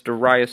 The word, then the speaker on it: Darayus: You can find my Twitter account by just typing in @NordoniaSports or my name, Darayus Sethna. That's DarayusSethna. If you can spell it, I'll be impressed. If you can pronounce it, I'll be even more impressed. That's Darayus Sefna.